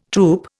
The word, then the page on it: troupe: Indian